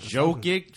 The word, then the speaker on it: Jokic: Oh, yeah, that's Jokic.